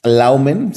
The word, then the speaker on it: Luimen: Eh, la pieza se llama Laumen, es como realmente se, pronuncia, es, es, una palabra en holandés que significa algo así como, como imaginación, como estado de ánimo imaginativo. En inglés lo traducen como moods, como, como ambientes, pero, pero bueno, se escribe Luimen.